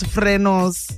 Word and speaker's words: frenos: The right way to say it, apparently, is frenos.